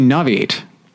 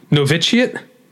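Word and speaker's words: Novitiate: Noviate? Noviate? – Noviciate? – Yep. – I don't know if I'm saying it right either, but I know you're closer than I am. – Yeah, so. You were definitely skipping over some syllables.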